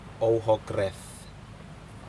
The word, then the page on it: ouhokred: Aneityum